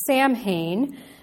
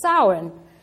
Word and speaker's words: Samhain: To my Americanized mind, it looks like it would be pronounced Samhain, but that is not the correct pronunciation of this religious festival. I saw a meme on Facebook this week that offered some help. The Irish pronounce this Gaelic word Samhain. In Wales, they say Samhain. The Scots say Samhain or Shaven.